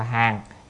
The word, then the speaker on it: hàng: nhưng mà lúc thì đọc hàng, lúc là đọc gián, ở đây phải đọc là hàng.